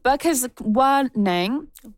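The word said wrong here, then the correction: Bkejwanong: let me try that again, Bugisnanwing territory, also known as the Walpole Island First Nation in southern Ontario.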